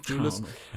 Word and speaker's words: Thewlis: Wie wird das gesprochen? Thewlis.